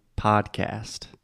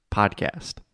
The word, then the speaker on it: podcast: In American English, we say podcast. So the first vowel sound, again, is the ah sound. Just like prato, chato, podcast.